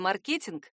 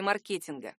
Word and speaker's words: маркетинг: Современные орфоэпические словари, в частности, Большой орфоэпический словарь русского языка 2012 года, разрешают и ударение «маркетинг», и ударение «маркетинг».